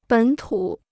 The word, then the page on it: 本土: běn tǔ